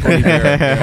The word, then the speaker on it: Bon Iver: Yeah, it's pronounced Bon Iver.